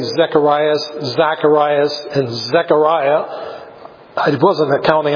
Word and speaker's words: Zacharias: Well, the problem is, the way to say it in Greek, which is what it's written, Luke is written in, is Zacharias.